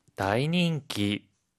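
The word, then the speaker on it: 다이닝기 (大人気): 다이닝기, 다이닝기. – 이때 다이는요, 클 대라고 하는 한자를 써요. 매우 커다란, 엄청난 이란 뜻을 나타내거든요. 그런데 이 클 대는요, 음으로 읽을 때는 다이, 뜻으로 읽을 때는요, 오라고 읽어요.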